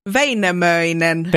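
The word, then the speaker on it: Väinämöinen: It's Vainamoinen.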